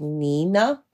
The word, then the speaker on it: Niina: So Nina is spelled N-I-I-N-A. So like Nina. – Okay. – And then Rei is spelled R-E-I.